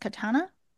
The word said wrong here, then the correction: Katarina: Katarina. – Katarina, Katarina.